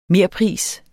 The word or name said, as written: merpris